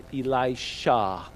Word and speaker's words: Elisha: Let me hear you say Elisha. Say it like that. Shah. Okay, why did I have you say that? Because a lot of times we get mixed up with Elijah. There's Elijah and Elisha.